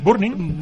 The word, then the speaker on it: Burning: ¿De Burning? ¿Cómo se dice? – Burning. – Burning, pero es Burning. – Sí, pero son madrileños, es Burning.